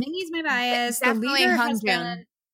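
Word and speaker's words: Hongjoong: What's the leader's name? Sorry, I don't pronounce it right. – Hong Jun. – Hong Jun. I would say Hong Jun.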